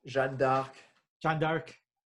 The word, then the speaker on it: Jeanne d'Arc: Jeanne d'Arc.